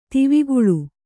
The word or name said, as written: ದೇವಕುಳ